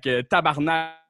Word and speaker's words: tabarnak: like tabarnak is a motherfucker. – Oh, nice. How do you say it again? – Tabarnak. – Tabarnak.